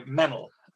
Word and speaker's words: Meynell: It rhymes with kennel or fennel or Reynolds, okay. – So Meynell.